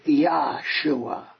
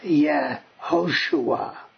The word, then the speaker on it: Yehoshua: So, if Yah was the proper pronunciation of the first syllable of God's name, then you would expect Joshua's name in Hebrew to be Yahshua. But it's not. It's pronounced Yehoshua. So the vowel, the Y-A-H, the A vowel that is vocalized at the end of the word does not appear in the beginning of the word.